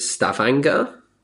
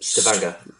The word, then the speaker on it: Stavanger: So before we play this last track, do I pronounce it Stavanger? – Yeah, perfect. Stavanger. Yeah. – Oh, great. That was lucky. – Most people say Stavanger.